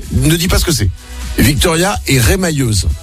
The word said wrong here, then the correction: remailleuse: C'est ce que c'est. – Remailleuse. – Ça se prononce pas comme ça. Remailleuse. – Remailleuse, oui. – Ah, remailleuse, pardon.